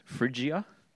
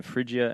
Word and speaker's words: Phrygia: Phrygia and Galatia. They went through the region of Phrygia and Galatia, having been forbidden by the Holy Spirit to speak the word in Asia.